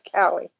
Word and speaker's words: Calle: She pronounces it Kelly.